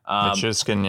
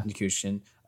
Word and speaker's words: Nichushkin: Like, they have their lines are Gabriel Landeskog, Nathan MacKinnon, Valeri Nichushkin. I don't know. I butchered that probably. – Nichushkin, yeah. – Nichushkin.